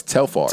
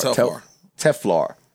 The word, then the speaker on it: Telfar: You ain't got Teflar money, nigga. – Yo, this nigga, yo. – Wait, am I saying it right? Is it Teflar? No, it's Telfar. – Telfar.